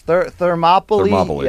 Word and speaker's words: Thermopylae: Thermopile or something. – Yes. Thermopylae. Yes.